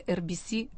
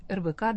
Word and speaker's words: RBC: И с нами Анастасия Матвеева, аналитик интернет-издания RBC RBK Daily. Как правильно говорить? – Можно и так, и так. – И так, хорошо. – Здравствуйте. – RBC Daily будем говорить, как по-английски. А начинаем с традиционной рубрики о событиях в мире прессы.